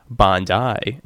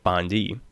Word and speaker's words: Bondi: And, first off, he wanted to remind Mark that the region or city is pronounced Bondi instead of Bondi.